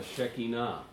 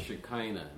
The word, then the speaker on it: Shekinah: Or as a Jewish rabbi would say, the Shekinah or the Shekinah. Maybe you've heard it pronounced. And both are correct, apparently.